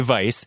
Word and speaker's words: Weiss: It's spelled with a W, wheat. Weiss, but you say vice, but it's called...